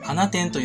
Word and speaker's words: 放出: 放出と書いて花店と読む地名が